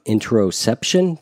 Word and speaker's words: interoception: Let's talk about the concept of interoception. Did I say that correctly? – You did, yeah.